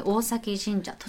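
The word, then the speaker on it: 大前神社: 大きいに前と書いて、大前と書いて大崎神社。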